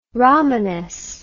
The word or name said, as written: romanes